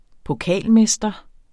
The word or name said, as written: pokalmester